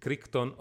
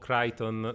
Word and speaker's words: Crichton: tratto dal libro del 1990 di Michael Crichton o Crichton, se, se vogliamo la pronuncia più originale e corretta eh, sicuramente dirò l'una e l'altra nel corso dell'episodio, salt- sbalzellando senza senso.